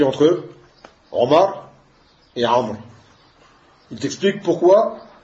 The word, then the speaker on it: Amr: Seulement, ce waou, on ne le prononce pas. Tu dis pas Amrou.